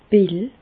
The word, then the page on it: d' Pill: Bas-Rhin; Reichshoffen